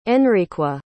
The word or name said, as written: enriqua